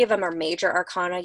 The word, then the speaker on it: arcana: major or a minor arcana? Am yeah. I saying that right? – Yes, major okay. and minor arcana.